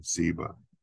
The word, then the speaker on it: Ziba: Then the king Simon Zeb, Zeb, Zeb. Is it Ziba? I, I, I said that wrong. Ziba.